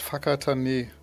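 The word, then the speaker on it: Whakatāne: In, in dem schönen Ort Fakatane. Das klingt ein bisschen seltsam, der Name, aber wird so ausgesprochen.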